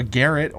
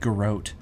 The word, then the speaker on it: garrote: Or a garret, or a garrot, you know, depending on... – I've heard it pronounced garrot. – Yeah, I've heard garrot, and I've also heard garrote. But I'm going to go with a garrot, which, honestly...